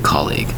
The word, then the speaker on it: colleague: It's two syllables for the word colleague, spelled C-O-L-L-E-A-G-U-E.